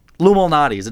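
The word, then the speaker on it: Lou Malnati's: Well, you're saying it quickly. Luminati's. It's two words.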